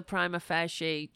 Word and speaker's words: prima facie: That remains a prima facie true. Is that how you pronounce that correctly now that I'm saying that? I feel like I might have said it wrong. Prima facie, right.